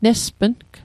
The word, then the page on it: nespng: Mi'gmaq